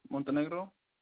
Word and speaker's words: Montenegro: Name's Marcos Montenegro. – Can you spell that last name for me, please? – Montenegro. – Excuse me, I have M-O-N-T. – T-E-N-E-G-R-O. – G-R-O.